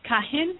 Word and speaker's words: kahin: Kahin is K-A-H-I-N.